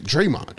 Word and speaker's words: Draymond: But I mean, I guess Draymond is doing it. Why did I say his name like that? Draymond. – Draymond.